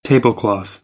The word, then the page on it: tablecloth: American English; ɑː